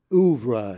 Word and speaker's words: oeuvre: and your what Larry David once described to me as oeuvre. He pronounced it with that long O.